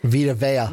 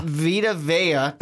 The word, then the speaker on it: Vita Vea: Um, we have Number 16, the Cowboys taking Vita Valle. – Vita Vea. – Vita Vea. Damn it, I knew I was going to say it wrong.